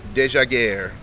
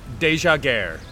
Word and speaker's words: Dejaegere: Teach the Tifos how to say this man's name. – We're talking, about the newest Charlotte FC midfielder, Brecht Desjaguerre. – Brecht Desjaguerre. – Yeah, D-E-J-A-E-G-E-R-E, Desjaguerre.